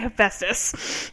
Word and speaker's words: Hephaestus: I'm like, oh, so that's how you say Hephaestus.